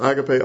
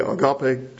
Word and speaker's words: agape: The one, agapao, agape, agape, depends on where you learned your Greek and how your professor pronounced it.